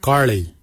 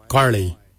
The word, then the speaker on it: giilyi: Darb is spelled D-H-A-A-B. It is a traditional knife, a darb. For boomerang we say garli. Garli is the traditional name for boomerang. Garli is spelled G-I-I-L-Y-I, garli. For the traditional stone axe, we say goj, goj. Goj is spelled G-O-O-D-J, goj.